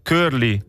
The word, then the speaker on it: Curly: Curly si pronuncia come se ci fosse una K. Ah, Curly, scusa, Curly.